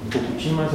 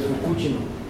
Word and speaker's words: Fukushima: Fukushima se le llama, quizás, pero la pronunciación, según tengo entendido, es Fukushima.